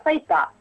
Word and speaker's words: сайты: Да, программисты говорят не сайты, а сайта. В профессиональной речи характерно как раз вот это ударение, да, это, окончание не «ы», а «а».